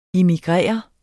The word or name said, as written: immigrerer